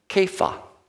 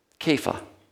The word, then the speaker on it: Kepha: His name would have been pronounced Kepha. Kepha.